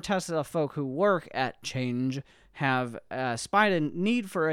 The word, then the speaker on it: Chanje: In less pretty but more realistic news, a California company called Chanje, which is spelled like change, but with a J instead of a G because it's from California, has announced a new electric car.